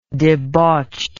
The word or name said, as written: debauchedness